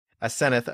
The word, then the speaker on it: Aseneth: Jill Hicks Keeton is Associate Professor of Religious Studies at the University of Oklahoma, the author of Arguing with Ascent, Aseneth, I always say that wrong, Arguing with Aseneth,